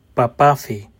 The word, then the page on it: Παπάφη: Greek